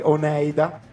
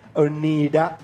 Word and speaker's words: Oneida: E oggi ci concentriamo su un gruppo di New York, i mitici Oneida, per leggerlo come si scrive. Si pronuncerebbe Oneida.